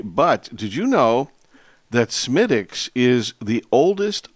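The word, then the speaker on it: Smithwick's: But so just in case you haven't experienced Smittix, which is spelled Smithwix, go get some, and you might like it a whole bunch.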